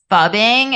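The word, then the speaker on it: phubbing: I think it's pronounced fubbing. It's P-H-U-B-B-I-N-G is how you spell it.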